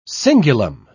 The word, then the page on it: cingulum: British English